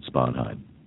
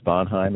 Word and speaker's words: Sponheim: David Sponheim. So you can write my name in, David Sponheim, in the upcoming election. The last name is spelled S-P-O-N-H-E-I-M.